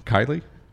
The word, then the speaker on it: Kiley: And I hope I don't butcher your last name. Kiley? – That's correct. You nailed it.